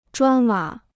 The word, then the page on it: 砖瓦: zhuān wǎ